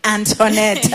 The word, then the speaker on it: Antonette: Yeah, oh, is it Antonette? – Antonette. – Oh, what a name. What a name. So if you're like me and you had mispronounced it, it's Antonette. Yes, you are most welcome.